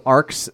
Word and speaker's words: ARCH: They're these tiny optical discs called ARCs, that which is spelled arch, but it's pronounced arc for some reason.